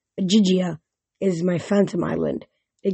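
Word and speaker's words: Ogygia: As to where you are, you're in Ojigia. She pronounced it like Ojigia.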